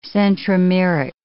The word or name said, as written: centromeric